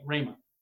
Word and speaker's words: rhema: The word here, again, is that Greek word rhema, transliterated R-H long E-M-A, rhema.